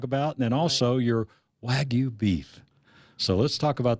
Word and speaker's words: Wagyu: So it's pronounced Wagyu. – Wagyu. – Wagyu is how you pronounce it in Japan.